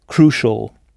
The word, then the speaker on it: crucial: Pas de problème avec crucial, c'est exactement le même mot en anglais, mais prononcé crucial.